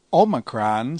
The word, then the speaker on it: omicron: Omicron sounds like the O in omelet. – We will pronounce it as the O in obey, as in modern Greek.